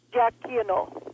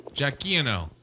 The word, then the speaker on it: Giacchino: It's Giacchino. – Giacchino. – Thank you very much. This is what. – This is why we have a live show, folks. And this is the cool Giacchino.